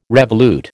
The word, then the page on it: revolute: American English